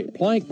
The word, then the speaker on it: Planck: Plank, by the way, we should mention Nina Plank is spelled P-L-A-N-C-K.